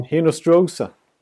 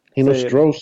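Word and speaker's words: Hinostroza: Nu är det Hartman och Hinostra, eller hur uttalar man han? – Hinostrosa. – Hinostrosa. Säger uttalsexperten här.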